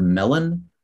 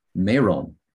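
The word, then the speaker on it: Melon (멜론): So like the word melon in Korean is melon, and it was once called in Korean, meron. It was a single leo. Meron. And so they doubled it. So it became Melon. – Meron changed to Melon. Okay. – Yeah. So because it was considered like a mispronunciation.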